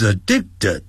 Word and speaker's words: addicted: He is addicted, 哦，要会念这个字，我要念慢点， addicted, addicted, T-E-D, 念成 ted, 不要念成 addicted, 最后那个 D 的音是切掉， addicted, he is addicted to playing, 那个 to 是介词， addicted 就是形容词，翻成上瘾的， to 要翻成针对，他针对什么是上瘾呢？